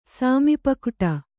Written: ಸಾಮಿಪಕ್ವ್ಟ

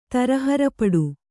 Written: ತರಹರಪಡು

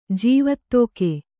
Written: ಜೀವತೋಕೆ

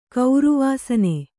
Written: ಕೌರುವಾಸನೆ

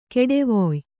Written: ಕೊಡುವಾಯಿ

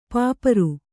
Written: ಪಾಪರ್

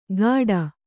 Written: ಗಾಡ

